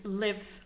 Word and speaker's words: Liyv: So that was Portland-based experimental pop with Liv. I think that's how you pronounce it. It's spelled L-I-Y-V.